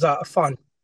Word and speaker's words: Afan: So we're blessed to have, I have trouble pronouncing your name. Can you introduce yourself to the audience? – Yeah, my name is Afan. Afan, and I appreciate you having me on here.